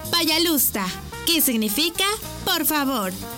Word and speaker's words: Пожалуйста: Пожалуйста. ¿Cómo? Пожалуйста. Una vez más. Пожалуйста. Y se escribe Payalusta. P-A-Y-A-L-U-S-T-A.